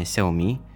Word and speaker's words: Xiaomi: Также 18 апреля, во вторник, всем известная компания Xiaomi, да, именно так это произносится правильно, представила новую продукцию.